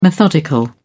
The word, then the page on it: methodical: British English